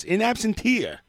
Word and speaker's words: in absentia: Tommy Rockstar, TRNN, in absence, in absentia, bot star news. Coming in absentia? How do you say that? – Absentia. – In absentia.